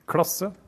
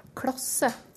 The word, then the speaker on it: klasse: Klasse. – Klasse. Klasse. – Klasse. Klasse. – Klasse. Klasse. Som du hører kan det uttales eh, både med tjukk L og med tynn L.